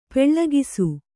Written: ಪೊಳಕಿಸು

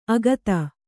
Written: ಆಗತೆ

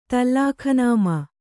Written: ತಲ್ಲಖನಾಮಾ

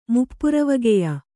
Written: ಮುಪ್ಪುರವಗೆಯ

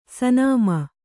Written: ಸುನಾಮಿ

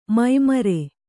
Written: ಮೈಮರೆ

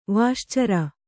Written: ವಾಶ್ಚರ

